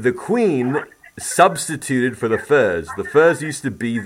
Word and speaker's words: ferz: furs. Have you heard of the furs? – The what? – Furs. It's spelled F-E-R-Z.